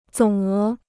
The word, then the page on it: 总额: zǒng é